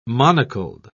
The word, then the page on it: monocled: British English